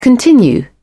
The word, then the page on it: continue: British English